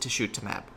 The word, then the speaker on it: tisotumab: Tisotumab, we don't know. This is new. So tiso kind of sounds like tissue, like tishutumab. In fact, some may even pronounce it tishutumab.